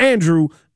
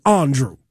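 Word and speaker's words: Andruw: I can remember where I was in 1996 when a fresh-faced Andrew Jones, and I say his name properly, it's not Andrew, it's Andrew, okay, came up as that, you know, baby-faced, chubby-faced rookie and hit those two home runs in old Yankee Stadium.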